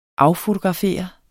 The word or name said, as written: affotografere